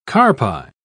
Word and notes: carpi: British English

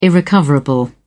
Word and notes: irrecoverable: British English

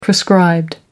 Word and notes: prescribed: American English

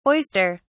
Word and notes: oyster (s): British English

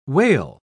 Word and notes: wale: British English

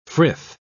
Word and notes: frith: British English

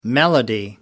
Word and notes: melody: American English

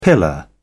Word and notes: pillar: British English

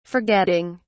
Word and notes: forgetting: British English

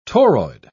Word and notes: toroid: British English

